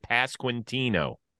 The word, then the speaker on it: Pasquantino: We're good with Pasquantino. – Yep, that's perfect.